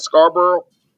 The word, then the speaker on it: Scarborough: Could you tell me a little bit about your journey, not just in Saudi Aramco, but your journey from Scarborough and Orangeville? And am I saying that right? Scarborough? – Scarborough, yeah.